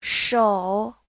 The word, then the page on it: shou: shou3